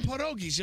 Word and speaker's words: pierogies: How about some pierogies? You have some pierogies? – Why do you say it like that? – Can you just... – That's how you say it in Polish. Pierogies.